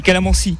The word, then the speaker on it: calamansi: Parce que là, on a aussi pomme, kiwi, verveine, mangue, écurie, calamansi, je le prononce bien. – Oui, calamansi, on dit.